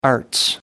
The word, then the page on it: arts: British English